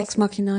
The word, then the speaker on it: Ex Machina: Ex Machina, ja.